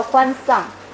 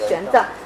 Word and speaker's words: 玄奘: 他一听说我是从中国来的，他马上呢就跟我说：“ 哦，那你知不知道有一个很有名的这个僧人出家人叫欢丧？他是用这个这个非常诡异的这个读音发的。” 然后呢，我就没反应过来，因为在那之前我从来不知道。当然，大家后来大家可以猜到这个是说的是谁啊？就是玄奘。